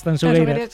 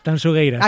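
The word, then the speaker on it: Tanxugueiras: Tanzugueiras. – Sí, esto me lo corrige Fermín Agustín mucho. Por favor, digo, mira que yo siempre pronuncio... A ver, otra vez, dímelo. – Tanzugueiras.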